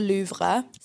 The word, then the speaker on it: Louvre: When they go to the Louvre.